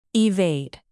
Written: evade

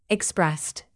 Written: expressed

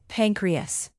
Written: pancreas

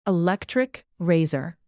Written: electric razor

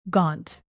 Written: gaunt

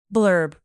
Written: blurb

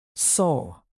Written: sole

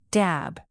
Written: dab

